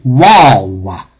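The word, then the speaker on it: waw (וו): The vav, or actually a vav is always pronounced in traditional biblical Hebrew as a W sound and not as a V sound. So it's a waw.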